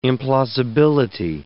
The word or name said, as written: implausibility